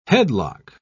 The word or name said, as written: headlock